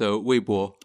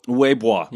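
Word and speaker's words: Weibo (微博): It's Leimo. – It's pronounced Weibo. – Weibo. – Yeah. – Yes. B-O-Bo.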